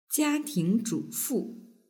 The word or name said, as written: jiatingzhufu